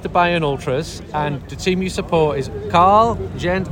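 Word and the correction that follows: Carl Zeiss Jena: Karl Zeiss Jena.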